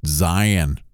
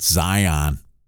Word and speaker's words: Zion: Attention people traveling to southern Utah, it's pronounced Zion, not Zion.